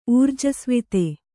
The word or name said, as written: ಊರ್ಜಸ್ವಿತೆ